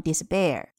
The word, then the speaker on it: despair: 那么 p 呢，在这个地方有一个浊化，读成 despair。